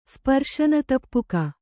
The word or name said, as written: ಸ್ಪರ್ಶನತಪ್ಪುಕ